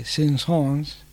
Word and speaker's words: Saint-Saëns: Pronunciado correctamente en francés sería Camille Saint-Saëns, nacido en 1835, fallecido en 1921, y este trabajo es del año 1886.